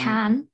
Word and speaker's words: Cannes: I think it's Cannes, but everyone gets it wrong.